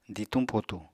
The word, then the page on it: dituñ-potuñ: apatani